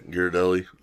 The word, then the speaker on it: Ghirardelli: Ghirardelli. – There you go. – Yeah. – No. Just no. – I don't know how to pronounce that name. – Ghirardelli.